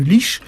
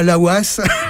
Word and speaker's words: Laois: Alors, on commence d'abord par le comté de Leash. Alors, Leash, si ça vous intéresse, ça s'écrit L-A-O-I-S. Hein, voilà, quand même, il faut... oui. Et non pas Laouas, comme certains pourraient encore le lire.